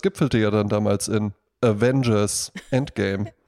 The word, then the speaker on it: Avengers: Und dann noch Fun Fact: Avengers kommt von Avenge, zu Deutsch rächen. – Also liegt die Betonung nicht auf dem großen A, also Avengers.